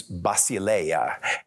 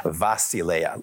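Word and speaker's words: basileia (βασιλεία): You know, the Greek word used in the New Testament for empire is basileia, and for George Theodore's benefit, in modern Greek, it's pronounced vasileia, like it starts with a V, but it really starts with a B.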